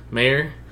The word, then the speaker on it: Maher: His kicker, Brett Maher. How do you say his name? – Mayer.